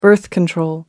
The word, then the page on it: birth control: American English